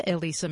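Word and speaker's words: Elissa: Do a web search on my name, Elisa Malcone, that's spelled E-L-I-S-S-A-M-A-L-C-O-H-N, or do a web search on Malcone's World.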